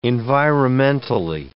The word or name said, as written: environmentally